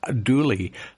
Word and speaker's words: Dula: The murder took place in 1866, and the gentleman's name was, surname was spelt D-U-L-A, but pronounced Dooley, and someone came along and wrote a song about it.